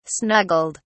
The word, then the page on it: snuggled: British English